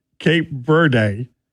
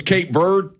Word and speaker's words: Cape Verde: It's not Cape Verde. – What is it? – The E is silent. – No. I know how to pronounce the Spanish word, V-E-R-D-E, Verde.